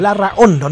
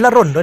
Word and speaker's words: Larraondo: Sí, es como, es como estamos en Chile, Larraondo, porque la H es mudita, no se pronuncia. Uh-huh. Larraondo, no es Larrondo, es Larraondo. Con Larraondo, doble R, y alarga un poco la sílaba de la H, entre la H y la O.